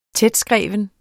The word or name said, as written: tætskreven